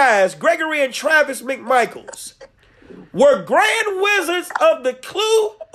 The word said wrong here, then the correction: Ku Klux Klan: I said it wrong, it's Ku Klux Klan, why would you run to that cross burning?